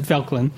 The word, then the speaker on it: Felchlin: how do you say Fecklin? – Uh, to Felchlin. – Felchlin.